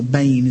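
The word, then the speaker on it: bane (בן): Well, if you look at the word that's translated old, the Hebrew is ben, or bane as it's pronounced.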